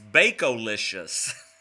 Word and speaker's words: Bacolicious: And the website is Baconlicious, except it's spelled B-A-C... Oh. No, it's not Baconlicious. It's Bacolicious.